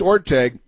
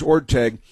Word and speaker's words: Orteig: and it's Orteig, the name is Orteig. The truth is, in the Spirit of St. Louis, the great movie classic Spirit of St. Louis with Jimmy Stewart as Charles Lindbergh, they also mispronounced the name Orteig in that movie with Jimmy Stewart, but here it is being mispronounced last night on Jeopardy as well."